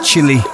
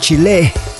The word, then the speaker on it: Chile: I normally say Chile, but the correct pronunciation, I believe, is Chile in South America.